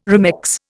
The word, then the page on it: remex: Indian